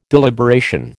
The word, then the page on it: deliberation: American English